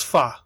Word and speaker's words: pho: You know, chat room says it's pronounced pho. – That's how Kelly pronounces it, and her friends pronounce it.